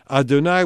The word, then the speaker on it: Adonai: Adonai, which doesn't at all correspond to those four Hebrew letters, and it means my Lord.